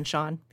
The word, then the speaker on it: Sean: She mispronounced her supposed husband's name, Sean. I guess she said it Sean. – She said C-N. – Yeah, yeah. C-N. And yeah. It should have been Sean.